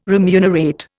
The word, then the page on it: remunerate: Indian